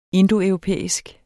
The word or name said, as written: indoeuropæisk